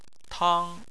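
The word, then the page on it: tang: tone 1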